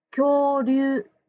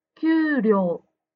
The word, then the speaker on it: 恐竜: そしてもう一つ、恐竜ですが、正しい発音は恐竜です。ジムさんは給料と言っていました。恐竜が正しいです。